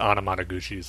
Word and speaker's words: Anamanaguchi: is it Anamanaguchi? – Yeah, I pronounce it Anamanaguchi. So. – Anamanaguchi.